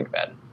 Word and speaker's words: McFadin: And you can follow me on Twitter with that exact same name, Daniel McFadden.